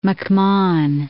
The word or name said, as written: McMahon